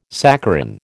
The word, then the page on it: saccharin: American English